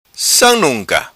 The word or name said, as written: Sam Nunca